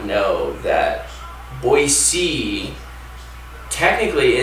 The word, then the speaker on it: Boise: But no, I chose to start in Boise, Idaho instead.